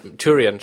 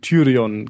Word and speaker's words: Tyrion: Ich muss übrigens, Tyrion spricht man den aus. Das letzte Mal gab es schon viel Gemecker in den Kommentaren, dass wir den irgendwie 4 oder 5 Mal falsch ausgesprochen haben.